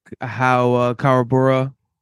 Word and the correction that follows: Kuwabara: I'm pronouncing that right, correct? – No. – Karubane? – Kuwabara. – Kuwabara. – I've definitely mispronounced it too. – At least that's how I pronounce it. – I keep thinking it's K-U-R-A, not K-U.